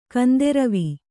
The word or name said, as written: ಗಾಂಧರ್ವ